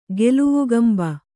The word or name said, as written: ಗೆಲವುಗಂಬ